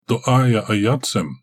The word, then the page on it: doaya ayat sem: Galindri